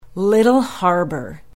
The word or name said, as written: Little Harbor